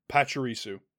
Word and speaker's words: Pachirisu: P-A-C-H-I-R-I-S-U. – Pachirisu.